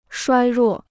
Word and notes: 衰弱: shuāi ruò

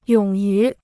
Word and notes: 勇于: yǒng yú

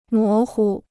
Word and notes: 模糊: mó hu

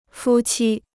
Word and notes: 夫妻: fū qī

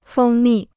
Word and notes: 蜂蜜: fēng mì

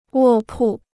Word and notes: 卧铺: wò pù

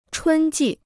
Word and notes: 春季: chūn jì

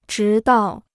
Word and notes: 直到: zhí dào

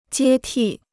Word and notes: 接替: jiē tì

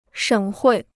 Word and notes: 省会: shěng huì

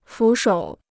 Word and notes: 俯首: fǔ shǒu

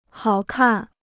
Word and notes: 好看: hǎo kàn

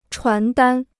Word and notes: 传单: chuán dān

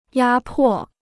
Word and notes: 压迫: yā pò

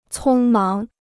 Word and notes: 匆忙: cōng máng

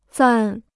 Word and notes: 暂: zàn

